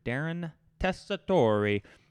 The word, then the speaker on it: Darren Tessitore: I had Mr. Darren Tessitore. He told me to pronounce it like that.